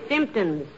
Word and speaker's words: symptoms: The word is symptoms, dearie, with the emphasis on the first syllable.